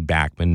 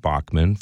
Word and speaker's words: Bachman: Randy Backman, and I'm pronouncing that properly, Backman, not Bachman, from Backman Turner Overdrive, the Canadian pronunciation, and the Guess Who, just hit it out of the park.